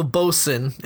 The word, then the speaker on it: boatswain: Well, bosun is the pronunciation. – It's how you pronounce it.